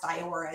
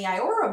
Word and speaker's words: Iora: I think it's pronounced Iora. It's I-O-R-A.